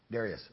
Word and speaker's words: Darius: It pleased Darius, or Darius. How do you like to pronounce that, my brothers? Darius or Darius? Darius, okay.